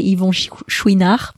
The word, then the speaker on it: Yvon Chouinard: Well, in French, we'd say Yvon Chouinard, but yeah, it's I don't know how you say it in English.